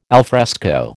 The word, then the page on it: alfresco: American English